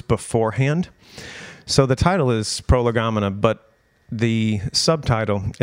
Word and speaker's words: Prolegomena: Title of the night, Prolegomena. Some of you are like, oh, that's how you say it. Some of you are like, what is prolegomena? It's from two Greek words put together. Pro, meaning first or before, and legain, which is to speak or words or to talk.